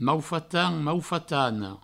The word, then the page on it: maufatan: maufatana; maufatano